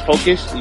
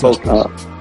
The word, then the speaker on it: Phocuz: Yeah, mine's is Phocuz. You spell it T-H-O. C is in cat, U, and then Z. That's Phocuz. – Mine is J-Mac the Producer everywhere. J-M-A-C. T-H-E-P-R-O-D-U-C-E-R, and that's on all platforms.